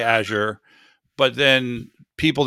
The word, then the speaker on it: Azure: Do you pronounce it Azure or Azure? – Azure. – You say Azure. – Yeah, that's how I always pronounce it, is Azure. – Is it when you talk to Microsoft people, is that how they pronounce it? – That is how they pronounce it whenever I've talked to anybody up at Microsoft Corporate. – Okay. Well, that settles that.